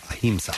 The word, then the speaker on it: Ahimsa: Ahimsa is what it's called. – A-H-I-M-S-A. – So I'm guessing that's how you pronounce it. Ahimsa.